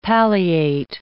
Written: palliate

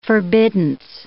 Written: forbiddance

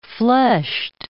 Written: fleshed

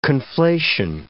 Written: conflation

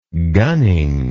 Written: gunning

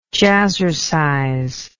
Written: jazzercise

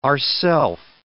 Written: ourself

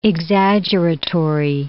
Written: exaggeratory

